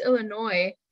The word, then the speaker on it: Illinois: I've lived in Illinois. – Wait a second. You say Illinois and people were like telling me, oh, it's Illinois.